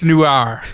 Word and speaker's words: Noir: How did you guys pronounce it? It's Noir. – Noir. – Noir. – Noir. – West Virginia spin. – It's French.